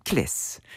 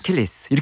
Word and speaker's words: Achilles: A가 발음이 잘 안 들려요. 모음이라서. 그래서 킬레스. – 킬레스. – 킬레스. 이렇게 들립니다. 잘 들어보시죠. 킬레스.